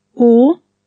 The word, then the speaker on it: att: Spelt O-C-H. – Dom. – Them, spelt D-E-M. – Or. – To, spelt A-T-T. – La. – Laid, spelt L-A-D-E.